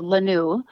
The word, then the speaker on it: Lanue: So this latest project of mine is called La Nu, L-A-N-U-E.